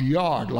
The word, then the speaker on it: yod: It's generally kind of pronounced yod, like it was Y-O-D, and some spell it that way, kind of the sound of it.